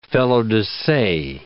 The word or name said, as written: felo-de-se